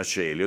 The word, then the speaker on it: Coeli: penso che tutto il Parlamento sarebbe a Regina Celi. Ho detto Re Bibbia prima, li mettiamo a Regina Celi. Si scrive Coeli, no? Ma si legge Celi, si legge Celi.